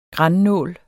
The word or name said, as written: grannål